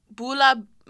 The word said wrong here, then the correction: Bouillabaisse: Boulabaisse? – Let me look at this. – Bouillabaisse. – Bouillabaisse. Okay. – Bouillabaisse.